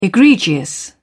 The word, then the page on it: egregious: British English